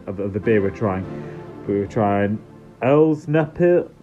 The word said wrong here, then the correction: Pilsner Urquell: Oh, God, now I've butchered it already. – Double butchering. – Say butcher one more time. – Meat cleave. – Elsner Pilsner? – Pilsner Urquell, I think. – Pilsner Urquell, there we go.